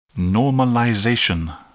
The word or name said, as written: normalization